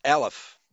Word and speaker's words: Aleph: But it was also always pronounced Aleph. It was always pronounced Aleph.